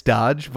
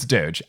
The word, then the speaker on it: Doge: I bet it's Dodge, but let's say it's Doge. – It's Doge. I mean, if it was Dodge, there'd be a D in it, right? – Yeah, just like luncheon.